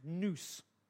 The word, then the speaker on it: nous: You know, the Greek word used for mind in that passage is pronounced nous.